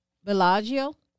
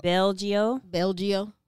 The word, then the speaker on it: Bellagio: So, this one, Bellagio. Did I say that one right? – Girl, you trying to get an A plus? You near it? – Yeah. – You said it right. – Okay. That's $12.99. – Say it the Geechee way.